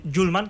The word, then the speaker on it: Hjulmand: De momento, el Atlético de Madrid parece cerca de cerrar un nuevo fichaje, el del centrocampista danés Morten Hjulmand, que así se pronuncia, que ha sido visto a lo largo del día por la capital.